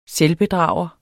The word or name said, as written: selvbedrager